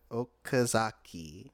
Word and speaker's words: Okazaki: was created by Takashi Ozaki, Ozakai, uh, Takashi Oz- Oka- Okazaki.